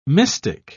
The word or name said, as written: mystic